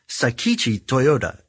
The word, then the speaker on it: Sakichi Toyoda: But let's start with Sakichi Toyoda. Am I saying that right? – Yeah. – I'm butchering it. – No, I think that's right.